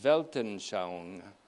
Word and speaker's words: Weltanschauung: It's called Weltanschauung, W-E-L-T-A-N. One word, S-H-A-U-U-N-G, pronounced Weltanschauung.